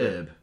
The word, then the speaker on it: herb: It seems that he was having, how they pronounce it in America? – Herb. – Herb. – Yeah, without the H.